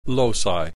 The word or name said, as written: loci